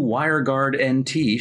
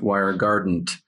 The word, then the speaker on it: WireGuard NT: New WireGuard NT shatters throughput ceilings on Windows. And it's not pronounced WireGuardant, as one of our producers thought, which I kind of like better.